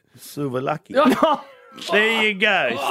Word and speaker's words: souvlaki: I can't say souvlaki. – Oh, Christ. That's an easy one. – Go on. – Souvlaki. That's how you say it. – There's no A in the middle of it.